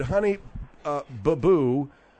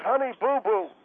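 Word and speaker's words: Honey Boo Boo: The proper pronunciation is honey boo-boo. It's not honey baboo.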